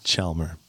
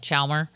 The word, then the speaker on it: Chalmer: You're close. – Can you pronounce? – It's Chalmer. – Chalmer. Yeah. – You kind of like skip over the L a little bit. Chalmer.